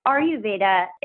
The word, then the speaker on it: Ayurveda: Yeah, so basically Ayurveda, it's a Sanskrit word. So a good way to think of pronouncing it is capital letter R, capital letter U, Veda.